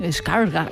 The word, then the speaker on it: Skarsgård: Y qué bien lo pronuncias. – Skarsgård. – Sí, Skarsgård. – Es como pronunciarlo como Dumbledore de Harry Potter, señor Skarsgård, es un poco así.